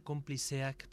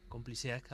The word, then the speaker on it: Konplizeak: Sí, se pronunciaría Compliceac porque esa Z tendría un poco, estaría más cercana a la S que a la Z Compliseac, algo Compliseac.